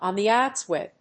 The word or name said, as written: on the outs with